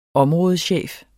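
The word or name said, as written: områdechef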